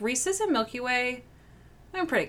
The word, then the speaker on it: Reese's: And by the way, it's pronounced Reese's. – Not Reese's.